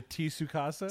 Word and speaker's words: Tsukasa: And then I finally, oh, there's a T in there. Yes, that makes much more sense. – Like a Tsukasa? – Tsukasa.